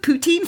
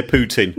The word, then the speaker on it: poutine: Comes with poutine fries. Episode 2. – Oh, I thought you were going to say Vladimir Putin. – No, poutine. Poutine. You have to just say it right.